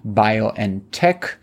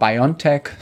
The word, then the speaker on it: BioNTech: They get a 340 million euro payout from their investment in the Mainz-based BioNTech, which is the German pronunciation of what Americans called BioNTech, and it is the company famous for producing the COVID vaccine together with Pfizer.